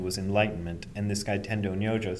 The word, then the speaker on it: Tendo Nyojo: But Dogen kept on in China, and he finally ran across this teacher who's, in Japanese, his name is pronounced Tendo Nyojo, and he's pronounced differently in Chinese, but I never can get it right, so I'll just use Tendo Nyojo.